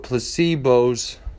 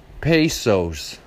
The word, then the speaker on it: pesos: He's gonna die when they see how many how many things that he did with placebos of, I'm talking about Spanish money, I'm sorry I didn't say it correctly, but the correctional services are listening to me. Pesos.